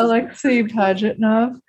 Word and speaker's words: Alexei Pajitnov: Alexei Pajitnov.